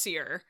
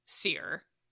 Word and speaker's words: Ciri: It's Seer. Her name is pronounced Seer, and I didn't realize that until I started listening to the audiobook. The advanced audiobook we got for Battle Scars. We'll talk a little bit about that. But yeah, that's my bad. Like, what in what world does that look like it's pronounced Seer? It should be Ciri. No. That's, come on. – Yeah. If it were C-E-E-R, then I would say Seer.